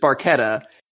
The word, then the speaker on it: Barchetta: the proper Italian pronunciation is just Barchetta, but it is sung with a CH sound by Geddy Lee.